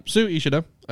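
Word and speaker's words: Sui Ishida: So how would you guys pronounce his name? Su Ishida? – Sure. – Yes. – Okay. Yeah, well, Su Ishida, creator of Tokyo Ghoul, is back with a new manga, Chojin X.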